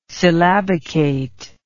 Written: syllabicate

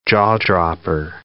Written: jaw-dropper

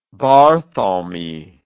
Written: barthelme

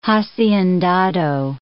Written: haciendado

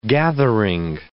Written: gathering

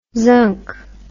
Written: zunk

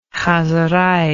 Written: chozerei

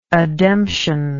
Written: ademption

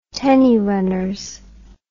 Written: tenny runners

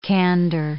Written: candour

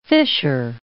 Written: fisher

